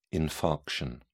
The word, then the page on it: infarction: British English